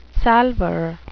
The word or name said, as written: salver